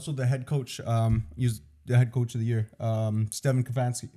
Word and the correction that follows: Stefanski: How do you say his name? – Stefanski.